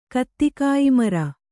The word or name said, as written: ಕತ್ತಿಕಾಯಿಮರ